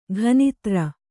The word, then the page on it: ಗಣಧರ: gaṇa dhara